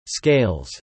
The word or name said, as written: scales